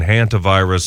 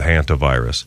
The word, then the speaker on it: hantavirus: And everyone is now, the pronunciation guides are all saying hantavirus. So I'll switch and go with hantavirus.